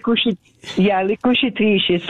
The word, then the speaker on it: Le Cucitrici: Can you pronounce the title for me? My Italian is rusty. Le Cucci. I'm not saying that right. Can you say that? – Le Cucitrici.